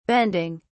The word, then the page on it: bending: British English